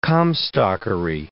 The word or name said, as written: Comstockery